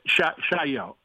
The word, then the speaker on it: Chaillot: Chaillot. The last Y-O would be like yo.